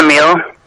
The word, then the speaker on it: Emile: It's Emile. – How do you spell it? – E-M-I-L-E.